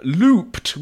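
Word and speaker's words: Loopt: Looped.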